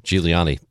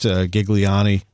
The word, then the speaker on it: Giuliani: Giuliani. That's the right way to say it. – Yeah. He told me once upon a time that you can say it anyway. You know, there's an Americanized Gigliani. I think he likes the Giuliani.